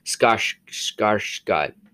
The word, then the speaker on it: Skarsgård: I literally had to research him, take a look at him, and say, how do you pronounce it? And it's Skarsgård. Stellan Skarsgård. It's Swedish.